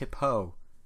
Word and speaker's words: Hippo: He's pronounced Hippo. Just a little more on the O.